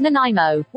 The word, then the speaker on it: Nanaimo: It's pronounced Nanaimo.